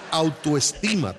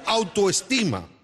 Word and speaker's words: autoestima: Eso es autoestima, Tres Patines. – Sí, alta esquina. ¿No lo dije bien? – No. A ver, diga conmigo. – Conmigo. – No sea tolete. Diga conmigo autoestima. – conmigo otra esquina.